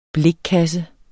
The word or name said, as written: blikkasse